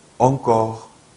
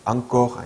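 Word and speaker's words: encore: 헌데부, 헌데부, 엉꺼, 엉꺼, 앙꺼 아니에요. 한국식으로 한국말로 표기할 때는 앙코르 이렇게 표기를 하는데요. 예, 발음할 때는 프랑스 원래 발음은 엉이 된다는 거죠.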